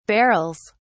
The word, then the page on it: barrels: British English